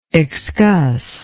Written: excussion

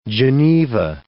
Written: Geneva

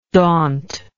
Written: dauntingness

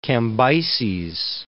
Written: Cambyses II

